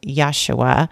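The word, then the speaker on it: Yeshua (ישוע): And the name Joshua is pronounced Yeshua in Hebrew.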